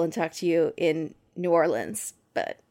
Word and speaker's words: New Orleans: You said it right. – Yeah, you did. Yeah, you did. I have to think about it so hard now each time. It's really stressful. – Just think L-I-N-S, New Orleans.